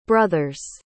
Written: brothers

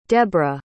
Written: debra

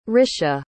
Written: risha

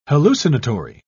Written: hallucinatory

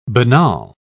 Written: banal

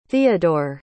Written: theodore

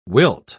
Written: wilt

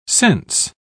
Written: cense

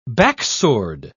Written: backsword

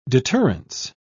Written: deterrence

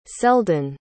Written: seldon

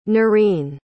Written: noreen

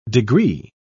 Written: degree

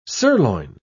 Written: sirloin

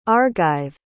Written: argive

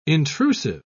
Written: intrusive

